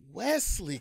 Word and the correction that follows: Wellesley: College. – Wellesley. – Studied the black. – Wellesley College. Take that again.